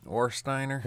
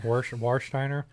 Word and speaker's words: Warsteiner: Warsteiner. – Warsteiner.